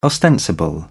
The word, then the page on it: ostensible: British English; ɒ